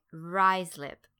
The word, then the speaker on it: Ruislip: How about this town in West London called Ruislip? That's right, Ruislip. I can tell you that that is not the first pronunciation that came to mind when I first saw that word.